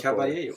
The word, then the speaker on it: Caballero: Ja, han kommer ju vara avstängd, så det är ju argentinaren som jag inte kan uttala namnet Caballero. – På. Tack så mycket.